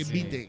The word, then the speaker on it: vitae: Es que ya ha pasado porque, o sea, el apellido ya normalmente ni se menciona, que es Vitae, que es como se escribe, que Vite, que como se pronuncia.